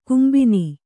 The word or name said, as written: ಕೆಂಬೊನ್